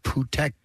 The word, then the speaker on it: Phuket: It's actually pronounced Phuket.